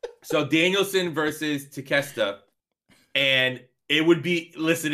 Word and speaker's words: Takeshita: Did I say that right? Takeshita? – No, close enough.